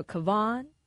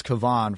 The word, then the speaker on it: Kevon: So Kevon. – It took the Warrior fan base a while. We didn't realize it was Kevon for a while.